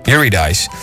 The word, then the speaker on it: Eurydice: Eurydice, zeg ik dat goed? Eurydice.